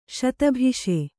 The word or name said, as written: ಶತಭಿಷೆ